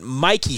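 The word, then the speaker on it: Michie: But Mikey Stadium, not Mitchie Stadium out there in West Point,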